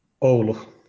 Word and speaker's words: Oulu: Oulu.